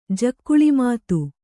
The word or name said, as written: ಜಕ್ಕುಳಿಮಾತು